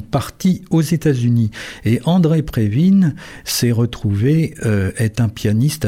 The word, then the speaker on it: Previn: Il faut prononcer Previn, nous on aurait plutôt tendance à dire Previn, hein. Mais c'est parce que c'est un musicien d'origine russe au départ,